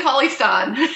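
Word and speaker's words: Cauley-Stein: Do you think Willie Lolly Stein will get drafted to the Nuggets? I may have butchered his name. – Willie Cauley Stein. – Cauley. There we go.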